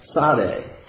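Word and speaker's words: tsadeh (צדי): tsadeh, and that has the sound of a TS, is how you pronounce that letter.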